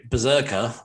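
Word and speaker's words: BRZRKR: So Berserker, which is spelt with no vowels. – Berserker! That's how you say it.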